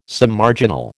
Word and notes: Submarginal: American English

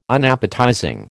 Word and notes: Unappetizing: American English

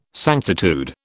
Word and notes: sanctitude: British English

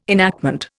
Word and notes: enactment: Indian

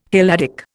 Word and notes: Helladic: Indian

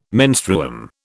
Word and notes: menstruum: British English

